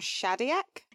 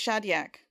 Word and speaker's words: Shadyac: I can't pronounce his surname. Is it Tom Shadyac? – Yeah, I think it's Shadyac.